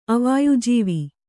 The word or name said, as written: ಅವಾಯುಜೀವಿ